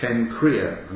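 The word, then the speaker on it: Cenchreae: This is this word diakonos or diakonos, a servant of the church which is at Kenchrea. I'm giving it a hard C.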